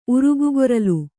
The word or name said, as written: ಉರುಗುಗೊರಲು